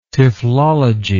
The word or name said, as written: typhlology